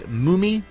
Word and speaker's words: Mumy: But he's also going to have the actor Bill, I believe it's pronounced Mumy, M-U-M-Y,